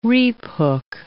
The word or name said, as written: reaphook